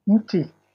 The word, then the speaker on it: mti: Mti. – Muti? – Mti is a singular. The plural is miti.